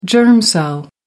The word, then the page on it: germ cell: American English